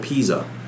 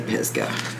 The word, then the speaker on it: Pisgah: I wonder if they pronounce it Pisgah or Pisa. Because up here in Ohio, they pronounce it Pisa, and it's... – No, like, it's Pisgah.